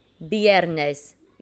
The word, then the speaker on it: viernes: Viernes. It's spelt with the V, but we pronounce it with a B.